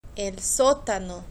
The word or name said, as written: el sotano